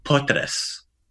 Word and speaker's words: potres: Okay, the word for earthquake is potres. – Potres. – Yeah. One more time. Potres. – Potres. – Very good. So there's that little soft S sound on the end.